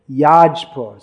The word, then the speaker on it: Jajpur: Oh, important place of Varaha, ancient place of Varaha worship is at Jajpur, which is a place of Yajna. That is actually Yajpur. So, but Oriya pronunciation is Jaj. So it's Yaj means Yajna.